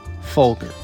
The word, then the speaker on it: Folger: Throughout the interview, Matias pronounces Folger with a hard G, Folger.